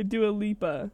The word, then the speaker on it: Dua Lipa: okay, here's Don't Start Now by Dua Lipa.